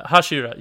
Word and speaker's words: Hashira: And I know there's other things that I'm missing, like when Pretty Boy, the Hoshira? Is that how you pronounce Hashira? It? Hashira? Yeah. – Where Tengen? – Hashira. Yeah, Tengen the Hashira.